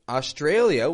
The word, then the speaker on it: Australia: And by the way, notice the contrast in the syllable stress there. How the tray goes strong. Australia. Play around with that yourself. Australia.